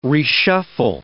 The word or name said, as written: reshuffle